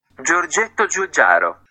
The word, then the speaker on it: Giorgetto Giugiaro: Giorgetto Giugiaro.